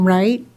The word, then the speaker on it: Wright: I'm Marianne Wright, and that's with a W-R-I-G-H-T.